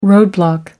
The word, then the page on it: roadblock: American English